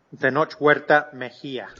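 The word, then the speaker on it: Tenoch Huerta Mejía: If I pronounced that one correctly, probably not. – Tenoch Huerta Mejia. – Okay, so no, then I didn't pronounce it. Not even close.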